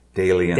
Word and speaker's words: Dalian: Alien with a D. Dalian.